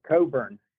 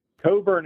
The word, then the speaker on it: Cockburn: It is spelled C-O-C-K-B-U-R-N. – Oh, Coburn. Coburn is the way they were saying it all night.